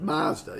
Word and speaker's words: Mazda: Mazda. Yeah, that's how you say it.